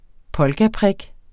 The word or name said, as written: polkaprik